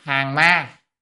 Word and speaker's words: hàng ma: Ngũ hàng ma, chữ này có hai âm hàng hay gián, phải đọc là hàng ma,